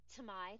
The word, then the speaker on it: Tomei: And I would also like to say the whole, like, yes, I love, I think you'd say her name, Marissa Tomei? – Tomei, thank you.